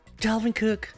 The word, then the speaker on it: Dalvin Cook: Dolphin Cook, ¿viste? ¿Viste? Mal como. ¿Cómo que mal como siempre? A ver cómo se pronuncia entonces. Ay, ay, lo hice igual. Dalvin. Dalvin Cook. Ahí está, para que veas.